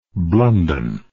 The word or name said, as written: Blunden